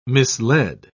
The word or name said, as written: misled